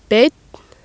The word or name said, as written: beinn